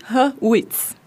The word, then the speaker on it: Hurwitz: Hurt, Não. Justin Hurwitz. Eu acho que eu falei certo.